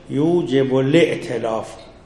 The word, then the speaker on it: یوجب الائتلاف: و یوجب الائتلاف، جهت تلفظ درستش یوجب الائتلاف، این حمزه ائتلاف.